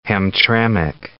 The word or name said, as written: Hamtramck